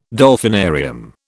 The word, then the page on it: Dolphinarium: British English